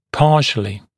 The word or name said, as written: partially